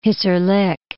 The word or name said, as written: Hissarlik